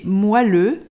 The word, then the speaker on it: moelleux: L'adjectif que l'on doit prononcer moelleux